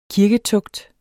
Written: kirketugt

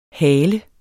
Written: hale

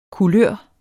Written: kulør

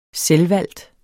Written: selvvalgt

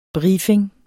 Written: BRIEFINGERNES

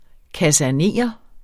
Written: kasernere

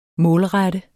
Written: målrette

